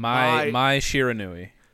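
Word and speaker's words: Mai Shiranui: My Shiranui. – My Shiranui.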